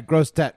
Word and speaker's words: Grosse Tête: And that's exactly what happened to one woman who was at a truck stop in Grosse Tête, Louisiana. Am I saying that right? – Yeah, Grosse Tête, big right. head. Right. French for big head.